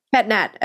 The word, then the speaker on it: Pét-Nat: PÉT-NAT.